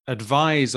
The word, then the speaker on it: advise: Advise, obviously, you've got a slightly different pronunciation with a A-D-V-I-S-E.